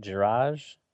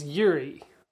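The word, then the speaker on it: Juraj: Is it Jiraj? Jiraj Sagan? I think it's Yuri. Yuri? Wow, that is not how I would read that.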